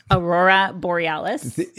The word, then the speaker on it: Aurora Borealis: Am I saying it right? Aurel Boreal. – Aurora Borealis.